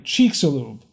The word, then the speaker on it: Chicxulub: I think it is pronounced Chicxulub. Oh, wait, Chicxulub. Yeah, that's it, because this is a long E.